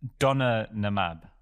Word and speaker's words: donanemab: It's donanemab. – How many? You, the first time you said it, there were five syllables. The second time you said it, there were four. Which one is it? – I think it's donanemab.